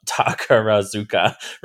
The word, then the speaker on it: Takarazuka: in the Takarazaku uh, Takarazuka Revue um, shows. Um, so I think I always mispronounce that.